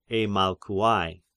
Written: Eimalkouai

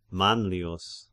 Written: Manlius

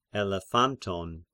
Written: Elephantine

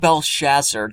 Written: Belshazzar

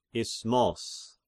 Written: Isthmos

